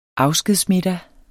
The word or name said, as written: afskedsmiddag